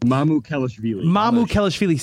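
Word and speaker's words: Mamukelashvili: Mama, I'm missing a syllable if I say that. Mamushkevili? – Yeah. – Help me out. – Yep. – Is that correct? – Yeah. – Oh, okay, good. – Mamukelishvili. – See, I knew there was another syllable in there.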